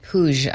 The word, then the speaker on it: Puig: Oh, so that's how you pronounce it, Puig.